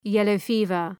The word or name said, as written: yellow-fever